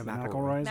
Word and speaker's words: McElroy: Yeah, McElroy's, McElroy's.